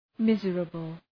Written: miserable

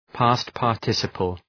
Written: past participle

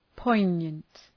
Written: poignant

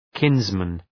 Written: kinsman

